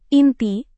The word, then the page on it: inti: indonesian